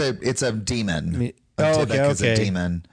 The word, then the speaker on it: Dybbuk: Have you heard of I can't pronounce this, D-Y-B-B-U-K, the Dybbuk box? – Dybbuk.